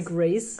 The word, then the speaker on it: Grace: Äh, wer tatsächlich relativ frisch in dem Film eingeführt wurde, war Chloe Gras Moretz. Wie, wie spricht man die richtig aus? – Also Gras bestimmt nicht. – Chloe, Chloe Grass. – Chloe Grace, Grace Moretz, würde ich sagen.